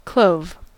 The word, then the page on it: clove: American English